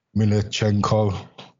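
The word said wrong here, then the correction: Mykolenko: Mikalenko, yeah.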